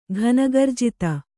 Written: ಘನಗರ್ಜಿತ

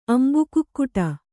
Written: ಅಂಬುಕುಕ್ಕುಟ

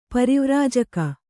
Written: ಪರಿವ್ರಾಜಕ

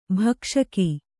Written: ಭಕ್ಷಕಿ

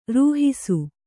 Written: ರೂಹಿಸು

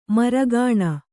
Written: ಮೆರುಗೆಣ್ಣೆ

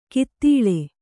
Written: ಕಿತ್ತಿಳೆ

